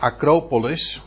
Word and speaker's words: Acropolis: De Acropolis, zo moet je het uitspreken, de, dat is eigenlijk de stadsburcht.